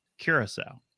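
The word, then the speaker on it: Curaçao: Well, it's nice when I have the thing in front of me now that says pronounced Curacao.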